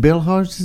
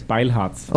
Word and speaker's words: Beilharz: Belhars, is it? – Yeah, that's kind of right. – That's close? – Close enough, yeah. – Yeah, how do you pronounce it? – In German? – Yes. – Beilharz. – Oh, Beilharz.